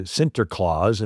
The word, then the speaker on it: Sinterklaas: But when you say Santa Claus, it's the Dutch pronunciation of Saint Nicholas. Right? So Saint Nicholas comes to Sinterklaas and finally Santa Claus.